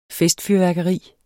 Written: festfyrværkeri

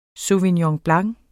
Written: SAUVIGNON

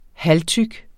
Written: halvtyk

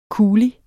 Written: kuli

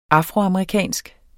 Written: afroamerikansk

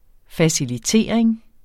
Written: facilitering

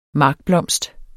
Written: markblomst

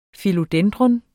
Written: Philodendron

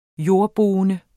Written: jordboende

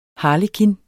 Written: harlekin

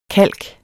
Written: kalk

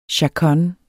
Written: chaconne

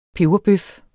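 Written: PEBERBØF

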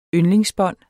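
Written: yndlingsbånd

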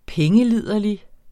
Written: pengeliderlig

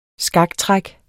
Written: skaktræk

